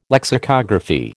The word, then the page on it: lexicography: American English